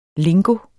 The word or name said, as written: lingo